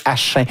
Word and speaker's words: Achim: A-C-H-I-M, comme dans Joachim. C'est pas Hakim, c'est Achin.